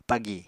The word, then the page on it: pagi: Apatani